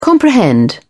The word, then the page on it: comprehend: British English